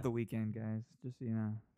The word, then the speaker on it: Weeknd: Honestly, and they could put the E, you know, because he just puts week and then the letter N-D. – Uh-huh. – And skips the whole E for whatever reason. – It's pronounced Weeknd. – Yeah, Weeknd.